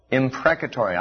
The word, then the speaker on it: imprecatory: Imprecatory, I'll spell that. I-M-P-R-E-C-A-T-O-R-Y.